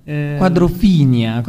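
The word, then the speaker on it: Quadrophenia: ed esce nel 1969 Tommy e nel 1973 Quadrofenia, credo che sia questa la pronuncia. – Quadrofinia, come ci suggerisce il buon Alessandro, il buon Alefonico qui al PC.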